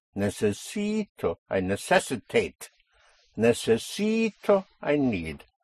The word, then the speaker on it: necesito: Here we have four syllables. Necesito. You have a vowel at the end, so you push down on the syllable before last, on the penultimate syllable, which makes it necesito. And then you come up on O.